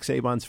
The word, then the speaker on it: Saban: This was Nick Saban's first year or second year.